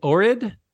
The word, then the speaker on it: Ohrid: Lake Ohrid? Is that my saying that right? – Yeah. O-H-R-I-D.